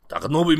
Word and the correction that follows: Takanobu: Do you like my pronunciation? – It's Takanobu.